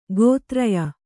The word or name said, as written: ಗೋತ್ರಯ